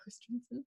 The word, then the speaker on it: Christensen: It's not the greatest at times, but I think Hayden Christensen, that's how you pronounce his name, right? – Yeah. – Christensen.